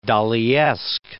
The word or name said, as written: Daliesque